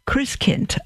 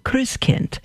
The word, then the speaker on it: Christkind: Christkind, pronounced Christkind, I should say, pronounced Christkind.